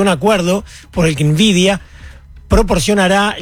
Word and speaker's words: Nvidia: Mientras tanto, la primera dama de los Estados Unidos, Melania Trump, anunció ayer una asociación estratégica con la empresa Nvidia, Nvidia se escribe, N-V-I-D-I-A, Nvidia, para incorporar la inteligencia artificial en las escuelas, en pleno debate sobre la regulación y los riesgos de esta nueva tecnología.